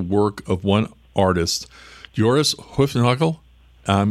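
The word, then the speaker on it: Hoefnagel: The Dutch artist names are a real chore, but it would be Hufnagel if you were doing it right. – The Dutch way. – You don't give a hard G.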